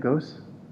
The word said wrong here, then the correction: Ghosh: It's actually Ghosh. The H at the, there's no H at the end, but there should be. Ghosh. – Ghosh, okay.